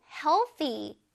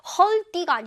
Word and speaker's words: healthy: 그 다음에, oh, healthy. healthy. 건강에 좋은 이란 뜻입니다. 한번 발음해 볼까요? healthy. 선생님 혓바닥 어디 있어요? healthy. 우리 친구들 잘해. healthy. 발음이 좀 어렵지. 헐띠가 아닙니다. 헐띠가 아니라 healthy.